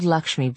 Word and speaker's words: Laxmi: I prefer bottled Lakshmi brand, and not only because it's my last name. It actually is spelled L-A-X-M-I, and I have sadly no financial interest in it, but it really is the best, better than the powders.